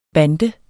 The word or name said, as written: bande